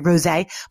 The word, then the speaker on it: rosé: I don't know if you call it like strawberry rose or strawberry rosé, but I like to pretend it's a rosé. So I'm going to go with the emphasis on the E.